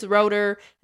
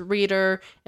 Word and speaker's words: Roeder: In the movie, it's Roeder. In the play, it's Reeder. In real life, it's both.